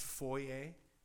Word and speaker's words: foyer: You're thinking that this is just one more example of my Canadian snobbery, you know, right up there with my insistence that that area over there is pronounced foyer.